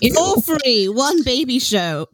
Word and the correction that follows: shoe: It's pronounced shoe.